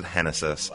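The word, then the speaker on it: Génesis: The two pitchers, left-handed pitcher Genesis Cabrera, spells his first name Genesis, pronounces it Genesis.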